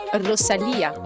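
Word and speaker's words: Rosalía: Voilà, donc vous êtes en train d'écouter Rosalia je l'ai bien prononcé Rosalia.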